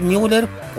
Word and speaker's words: Müller: Oye, es Müller por la 10 porque se escribe Müller con dos puntitos, ¿verdad?